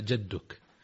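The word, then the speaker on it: جَدُّك: يقول هذا الأخ المرسل: نقرأ في صلاتنا في دعاء الاستفتاح قوله: وتعالى جدك، هل جدك تنطق بفتح الجيم أم بكسرها، وما معناها، جزاكم الله خيرا؟ – الجواب: النطق الصحيح هو بفتح الجيم، ومعناه العظمة والجلال، أي على جلالك وعظمتك، وبالله التوفيق.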